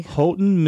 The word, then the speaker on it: Houghton: Houghton. – Like the stress is on the first syllable.